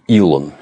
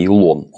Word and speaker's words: Илон: Он, кстати, Илон. Если кто не знает, он не Илон, он Илон Маск. Точно так же, как iPhone, iPad, Facebook. На первом слоге ударения.